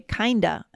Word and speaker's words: kinda: And just a quick pronunciation note, oftentimes native speakers will say kinda and sorta. – Mm, good point. Right? Right? We rarely yeah. will emphasize that F at the end.